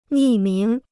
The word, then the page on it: 匿名: nì míng